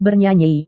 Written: bernyanyi

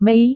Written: mei

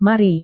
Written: mari

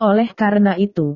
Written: oleh karena itu

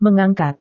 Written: mengangkat

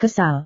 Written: kesal

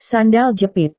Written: sandal jepit

